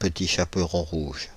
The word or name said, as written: Petit Chaperon rouge